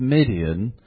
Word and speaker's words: Midian: Now, you may already know that the Battle of Midian involves a character called Gideon. And that's a good way to remember it. Midian rhymes with Gideon.